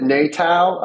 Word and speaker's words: NATOW: Um, I mean, as a part of a Native American tourism of Wisconsin or NATOW, and I always say, pronounce it Natal. Uh, we are a democratic organization where all 11 federally recognized tribes have an equal say and a representative, but we are not NATO.